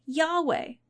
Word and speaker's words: Yahweh: So, people stopped pronouncing those four letters, Yod Heh Vav Heh, and if it wasn't for some old Greek documents from the time of the life of Jesus, we wouldn't know that they pronounced it Yahweh.